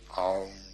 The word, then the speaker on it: AUM: It's written A-U-M. You start in the back of the mouth, ah. And then ooh, you fill the mouth. And mm closes at the mouth. And when you have pronounced this properly, all vowel sounds are in that pronunciation, om.